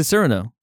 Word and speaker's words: Cyrano: I always pronounce it Cyrano. – Why? – But is it Cyrano? – Yes.